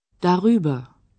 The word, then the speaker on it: darüber: Між «da» та «über» вставляється «r». Так слово краще вимовляти. – Darüber.